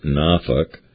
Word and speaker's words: Norfolk: Michael says she's lived with her husband, who's in the Navy, and three daughters at the Norfolk, right, spelled Norfolk, but it's pronounced Norfolk, Naval Station since 2016.